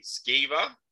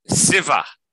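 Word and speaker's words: Sciver: So how do I pronounce this again? Skiver? – Siver. – Siver. So the C silent. Okay, Siver. So yeah.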